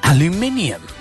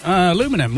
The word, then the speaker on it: aluminium: What how do you say aluminium? And he goes, aluminum. Why, how do you say it? And he goes, how it's spelt.